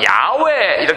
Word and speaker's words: 야훼: 아니면 이거 자체가 하나님의 이름 해갖고 여기서 그래가지고 이제 자음을 적당히 자음만 있으니까 몸을 넣어갖고 여호와 이렇게 발음하는 사람도 있고 어떤 사람은 야훼 이렇게 발음하는 사람도 있고 한데요.